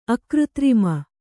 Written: ಅಕೃತ್ರಿಮ

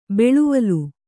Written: ಬಾಳ್ವಲಿ

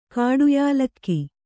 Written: ಕಾಡುಯಾಲಕ್ಕಿ

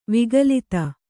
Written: ವಿಗಲಿತ

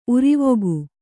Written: ಉರಿವೊಗು

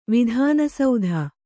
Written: ವಿಧಾನಸೌಧ

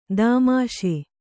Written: ದಾಮಾಷೆ

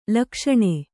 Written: ಲುಕ್ಸಾನ

